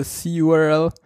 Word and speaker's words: curl: Curl oder wie es richtig heißen würde, c CURL ähm, steht irgendwo in diesem Blogpost, dass das eigentlich die richtige Ach, Faxen.